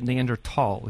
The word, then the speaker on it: Neanderthal: They pronounce it Neanderthal.